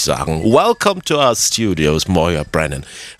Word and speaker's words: Moya Brennan: Ja, man spricht meinen Namen wirklich Moya Brennan aus. Ich habe die Schreibweise zu Moya, M-O-Y-A, geändert, weil alle meinen gälischen Namen falsch aussprachen, erzählt Moya. Deshalb schreibe ich es jetzt so, wie man es ausspricht.